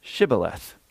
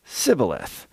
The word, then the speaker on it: shibboleth: And when he said, no, they said to him, then say, Shibboleth. And he said, Sibboleth, for he could not pronounce it right.